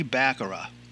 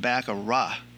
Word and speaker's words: baccarat: So if you want to try a card-based table game, I'm suggesting trying mini baccarat. First, remember the game is pronounced baccarat, not baccarat.